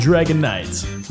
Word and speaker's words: Dragonite: As most of you already know, the proper pronunciation is Dratini, Dragonair, and Dragonite.